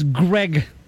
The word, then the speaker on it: Greg: But here is Greg. It's not Craig.